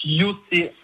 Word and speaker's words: Yossé: Alors, ça se prononce Yossé. Yossé, bien. Je ne savais pas où il fallait mettre l'accent. Je ne savais pas où il fallait mettre le, la, le son S ou Z.